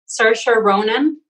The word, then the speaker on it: Saoirse Ronan: Me parece que lo estoy pronunciando bien. Saoirse Ronan, claro.